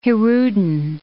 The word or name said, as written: hirudin